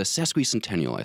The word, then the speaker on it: Sesquicentennial: and I can never pronounce it correctly, the Sasquatch Centennial, I believe is how you pronounce it. – Yeah, we all meant what, you know? So the Sesquicentennial, I think, but that is a $5 word for sure.